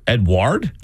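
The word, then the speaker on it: Edouard: Edward? – Yeah, it's spelled weird. It's not like Edward. It's E-D-O-U-A-R-D.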